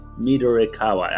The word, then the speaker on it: Midorikawa: Midorukawa, that's uh, Midor Midorikawa.